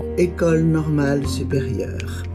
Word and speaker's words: École Normale Supérieure: École Normale Supérieure.